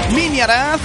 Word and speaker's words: Minhyriath: Pertenece a una banda suiza llamada Miniaraz. Se escribe M-I-N-H-Y-R-I-A-T-H.